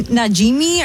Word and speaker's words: Najimy: Kathy Najimy.